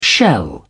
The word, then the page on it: shell: British English